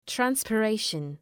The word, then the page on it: transpiration: ,trænspı’reıʃən